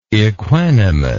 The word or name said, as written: equanimousness